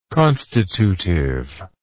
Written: constitutive

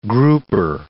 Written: grouper

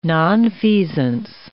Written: nonfeasance

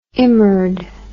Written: immerd

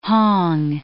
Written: hong

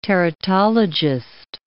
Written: teratologist

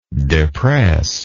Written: depressibility

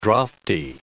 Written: draughty

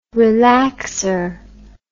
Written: relaxor

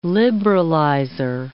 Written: liberalizer

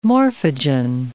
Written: morphogen

